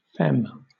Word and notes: femme: British English

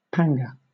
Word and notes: panga: British English

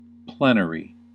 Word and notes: plenary: American English

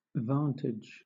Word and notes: vantage: British English

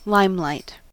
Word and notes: limelight: American English

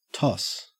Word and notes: toss: Australian English

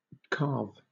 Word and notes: calve: British English

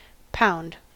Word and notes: pound: American English

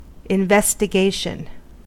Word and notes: investigation: American English

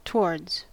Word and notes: towards: American English

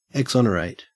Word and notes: exonerate: Australian English

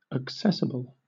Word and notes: accessible: British English

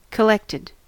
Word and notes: collected: American English

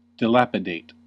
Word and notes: dilapidate: American English

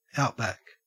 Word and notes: outback: Australian English

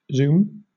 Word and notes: zoom: British English

